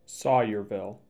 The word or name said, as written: Sawyerville